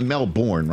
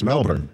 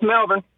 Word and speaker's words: Melbourne: It's not Melbourne, right? It's Melbourne, right? Did I say that right? – Yeah, it's Melbourne.